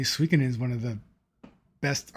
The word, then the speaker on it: Suikoden: How do you say that? The Suikoden. – Yes, Suikoden. Yeah, Suikoden's right.